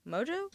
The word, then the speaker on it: Mongeau: Mongeau? Is that how you say it? I think I that's know. it. – Okay. I think it is.